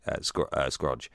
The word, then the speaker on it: Scrooge: You play as the lead character, Scroogey.